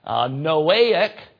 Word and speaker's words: Noahic: We would pronounce Noah, and so you look at that and you go Noahic, but the A is long if you're going to pronounce that. Noahic covenant.